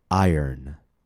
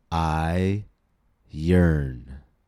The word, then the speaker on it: iron: And last but not least, one of the most strangely spelled words in English, iron. I-R-O-N. Iron. Let's break it down into two syllables. I yearn. I yearn. Now let's blend those two syllables together. Iron.